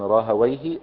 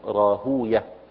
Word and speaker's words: راهويه: طريقة النحاة يعربونها فيقولون راهويه، وطريقة المحدثين ينطقونها كنطق الفرس لها يقولون راهوية، يعني زي سيبويه وسيبوية ومردويه ومردوية وهكذا.